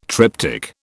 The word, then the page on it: triptych: British English